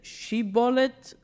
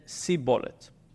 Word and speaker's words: shibboleth: Now, it turns out that these two tribes pronounce the plant name, the name of the plant, shibboleth, in a different way. One of them said shibboleth, one of them said sibboleth.